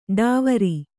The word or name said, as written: ಢಾವರ